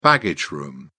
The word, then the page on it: baggage room: British English